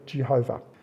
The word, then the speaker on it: Yahweh: We don't know exactly how it's pronounced. Yahweh is probably the best pronunciation that we have. Sometimes people have called it Jehovah.